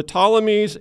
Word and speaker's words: Ptolemies: That's the silent P. The Ptolemies